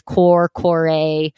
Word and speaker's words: Kore: And the Greek version is spelled K-O-R-E. So that can be pronounced different ways. Corey.